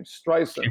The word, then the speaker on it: Streisand: Streisand, actually, that's you pronounce the name Streisand. – Streisand, yes.